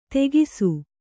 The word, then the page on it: ತೆಗಿಸು: Kannada